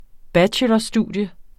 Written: bachelorstudium

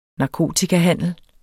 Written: NARKOTIKAHANDEL

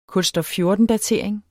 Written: kulstof 14-datering

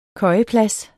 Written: køjeplads

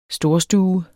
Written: storstue

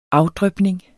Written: afdrypning

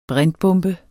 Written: brintbombe

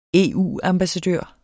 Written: EU-ambassadør

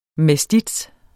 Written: mestiz

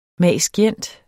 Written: mag.scient.

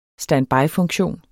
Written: standbyfunktion

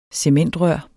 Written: cementrør

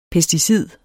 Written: PESTICIDER

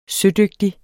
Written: sødygtig

